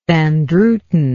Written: van druten

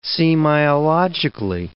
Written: semiologically

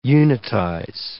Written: unitize

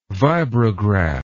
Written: vibrograph

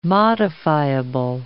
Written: modifiable

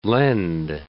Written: lend

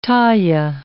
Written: taille

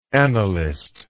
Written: annalistically

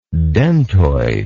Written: dentoid

